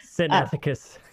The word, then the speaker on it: Sinaiticus: So I'll first kind of address, I mean, first of all, what is the Codex Sinaiticus? If I'm not saying it right, please correct me. – Sinaiticus. – Sinaiticus. So basically, at least that's how I've heard scholars say it.